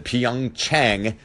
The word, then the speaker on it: Pyeongchang: I had to look it up, but that is how you pronounce it, Pyeongchang, South Korea.